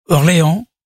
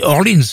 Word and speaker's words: Orleans: Orléans, un disque, un groupe qui s'appelle Orléans, mais qu'on doit prononcer Orleans, parce qu'ils sont américains, Ambrosia, Daryl O'Leary Jones, Al Stewart, America, Steely Dan, Bo Scaggs, Jerry Rafferty.